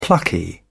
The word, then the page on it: plucky: British English